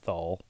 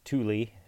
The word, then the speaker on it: Thule: I thought it was Thull or Thully, Thull, but it's Thule.